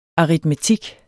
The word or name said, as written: aritmetik